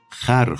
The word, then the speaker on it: خور: که به اقتضای قافیه کلمه خور به معنی خورشید را در مصراع نخست خر خواندیم